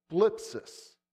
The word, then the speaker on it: thlipsis: In ancient Greek, this word, I'm going to try to say it, is thlipsis. It's a T-H-L-I-P-S-I-S. That's how we would pronounce it anyway.